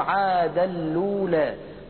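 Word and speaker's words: عادًا الأولى: الكلمة الثانية كلمة عاد اللولى، ورش رحمه الله لا يكسر التنوين في كلمة عادا، بل يبقيه ساكنا ويدغمه في لام التعريف، فيصير النطق بلام مشددة هكذا عاد اللولى، وهو يقلل الألف في كلمة ألولى فيصير النطق عنده هكذا عاد اللولى.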